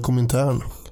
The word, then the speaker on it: Komintern: Och för den som inte förstår vad jag säger när jag säger Komintern, så kanske man också kan uttala det Komintern.